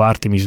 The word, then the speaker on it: Artemis: E in generale l'architettura Artemis, io mi leggo alla pronuncia americana perché vivo a Houston, sono abituato a pronunciarla in questo modo, dico Artemis non perché lo preferisca, sono abituato a sentirlo pronunciare Artemis.